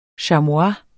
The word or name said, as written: chamois